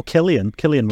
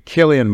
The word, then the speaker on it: Cillian: Oh, Cillian Murphy. – Cillian Murphy.